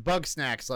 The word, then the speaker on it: Bugsnax: It's probably pronounced Bugsnax, like food, snacks. – Yeah, it is.